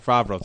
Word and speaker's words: Favreau: Favreau, thank you.